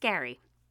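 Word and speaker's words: Gerry: So, every Monday, we're going to be finding Jerry, but it sounds like, oh no, guys, it's pronounced like Gary, not Jerry.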